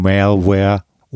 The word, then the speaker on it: Malware: Den Begriff Malware haben Sie bestimmt schon mal gehört oder vielleicht auch äh, etwas breiter US-amerikanischer ausgesprochen Malware oder auch Schadsoftware ist der Überbegriff.